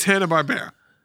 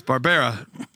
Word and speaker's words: Hanna-Barbera: It's Hanna-Barbera. – Really? Like for real? – Yes. – Yeah, yeah, always been Hanna-Barbera. – They audibly said it on some shows. Hanna-Barbera. – Now, in your defense, it's not like it's spelled that much differently than Barbara. – It's Barbara. – It's Barbera.